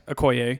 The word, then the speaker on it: Okoye: is it Okoye? – Yeah. – Okoye.